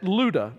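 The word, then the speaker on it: Lydda: He came down also to the saints who lived at Luda, which is how you pronounce that word.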